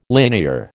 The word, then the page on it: linear: American English